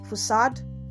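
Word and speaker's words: façade: Did you know that the word spelled facade is pronounced as facade? Yes, and not facade or facade. No. And also the word spelled p-e-u-g-e-o-t is not pejot.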